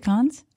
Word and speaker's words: Cannes: Is it Cannes? – Cannes. – The S is not silent. Cannes. – No. – Cannes. Cannes. Got it. Rhyming with Hans.